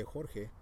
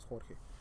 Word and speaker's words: Jorge: they couldn't say Jorge. So they changed my name to George because they can pronounce George, but they can't pronounce Jorge.